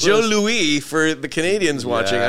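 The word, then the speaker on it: Jos Louis: It's J-O-S, but we pronounce it Joe Louis.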